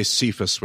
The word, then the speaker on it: Cephas: I always say Cephas when it's in the transliterated Cephas. English. Yep. – Oh my gosh. You are Simon, son of John. You will be called Cephas, which when translated is Peter.